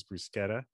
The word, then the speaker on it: bruschetta: It's like, well, one of those, because the correct pronunciation from an Italian point of view is bruschetta, because that's how you pronounce the CH.